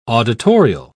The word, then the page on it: auditorial: British English